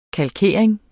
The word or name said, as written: kalkering